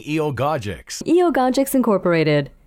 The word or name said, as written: Eogogics